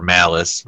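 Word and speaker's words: Mallus: But, you know, I think after he sacrificed himself to kill the demon Mollus, or Malice, rather, is how it's pronounced, pretty much the show just progressively went into the comedy territory after that.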